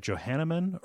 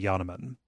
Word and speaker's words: Yanneman: There's one other small piece of feedback that I got from a couple of people, in the Minerva's Den episode, we mispronounced the name of one of the developers. So Steve and Carla, but then I think we said Johanneman or something like that. It's actually Yanneman.